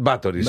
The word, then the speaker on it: Báthory: Una plana i una aguda, no? Ho dic bé, Elisabet sí. Elisabet Báthory, sí, deu ser així, deu ser així.